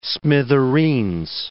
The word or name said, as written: smithereens